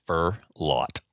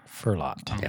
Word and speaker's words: Furlot: Furlot. – Furlot. – Furlot. – Yeah, like a lot of fir trees.